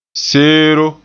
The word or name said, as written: seero